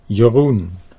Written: Jeroen

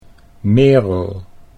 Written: Merel

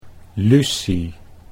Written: Lucie

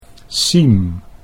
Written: Siem

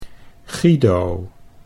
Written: Guido

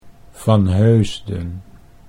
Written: Van Heusden

